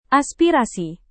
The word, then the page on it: aspirasi: indonesian